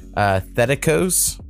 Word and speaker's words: Thetikos: and he told me he believes this artist's name is pronounced Thetikos. – Ooh, I'm going to pop that. – T-I-K-O-S.